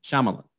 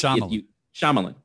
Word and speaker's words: Shyamalan: Which apparently, it's not, the way you pronounce it, it's not, we always hang the lawn longer. It's like Shyamalan. It's actually Shyamalan. Like you just, Shyamalan. – You just kind of cut it.